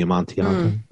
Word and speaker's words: Amontillado: Cask of the Amontillado, or what? How is it? – Mm-hmm. You're right. Very good. – Pronounce that properly. – No, you got it.